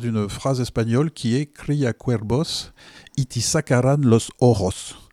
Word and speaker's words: Cría Cuervos: Le V, on dit bien B en espagnol. – Cría Cuervos, oui.